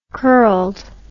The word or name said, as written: curledness